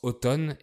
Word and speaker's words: automne: Alors, on prononce automne et le M est muet.